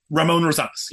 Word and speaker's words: Rosanes: Is that how you? – Ramon Rosanes. Yes, Rosanes.